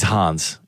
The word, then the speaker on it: Hans: Mandy, are you a Hans fan? – Yes, I am, yes. – He's Hans. He's German. – I was trying to rhyme with fan.